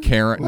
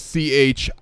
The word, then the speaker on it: Chiron: Charon. Like, no, it's not Charon. – Okay. – It's Chiron.